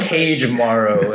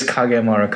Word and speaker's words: Kagemaro: Well, Cage Maro, as some people have pronounced it. But right. It is Kagemaro.